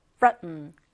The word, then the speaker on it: threaten: Threaten, threaten 이란 단어요. TH가 th. 이렇게 약간 문, 혀를 문 발음입니다. threaten이라고 발음이 되고요.